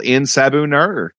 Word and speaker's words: En Sabah Nur: It is called In Sabu Nur. – Yeah. Yeah. Kudos on the pronunciation, brother.